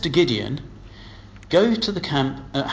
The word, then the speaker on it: Gideon: Gideon rhymes with Midian.